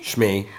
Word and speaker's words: Shmi: Is it Shmi? – Shmi. Shmi Skywalker.